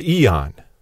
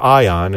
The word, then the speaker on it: aion: Now, the word age there is the Greek word eon, or ion is really the word. Its Greek is A-I-O-N. But I like to pronounce it eon because that's more an English pronunciation, easier to say in English than ion.